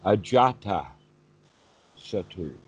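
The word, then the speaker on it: Ajātasattu: Because that long A is there. Ajara. No, is that a T? No. Ajata Sattu. With the emphasis on that second A, ajata.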